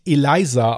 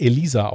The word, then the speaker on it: Elisa: Der Name wird nicht Eliza ausgesprochen, wie ich es des Öfteren gerne mal machen wollte. Der Name wurde wirklich Elisa ausgesprochen und der Familienname Lamb.